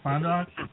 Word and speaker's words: Fond du Lac: Did I pronounce that correct, Heather, at Fond du Lac? – Yep, that's right.